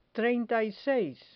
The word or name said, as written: treinta y seis